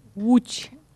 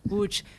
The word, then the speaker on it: Łódź: Łódź, pero se escribe L-O-D-Z con la O tachada, ¿no? Por eso se pronuncia tan complicado, ¿no? – Sí, W también tiene una. – Perdón, la L está tachada, sí. La L está tachada, sí. Claro, la L. Uh-huh. Y por eso se pronuncia como una U.